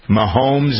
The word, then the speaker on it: Mahomes: Patrick Mahomes, is that how you pronounce his name? – It is.